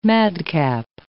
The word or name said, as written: madcap